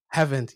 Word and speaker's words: Havant: but I think it was somewhere in Havant. Did I pronounce it right? – Havant, yes.